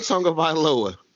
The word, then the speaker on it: Tagovailoa: If Tua Tagovailoa is not drafted by the Miami Dolphins, he will literally jump ship.